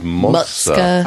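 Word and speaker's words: Moksa: I call it Mosca, but I've also heard Muska. – Oh, yeah. That was Muska.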